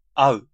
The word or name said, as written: meet